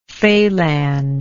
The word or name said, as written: falangism